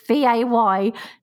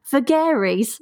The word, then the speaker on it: vagaries: Like, so I had to put in brackets vaygorees because I kept saying vagaries.